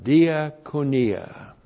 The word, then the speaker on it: diakonia: And then we have diakonia. Diakonia, D-I-A-K-O-N-I-A, diakonia. A couple of things. This word, diakonia, comes from the verb diakonao. Diakonao, D-I-A-K-O-N-E, long O.